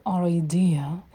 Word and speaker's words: our: Вот здесь можно отчетливо уходить в «о» звук. «Our idea», «our uncle».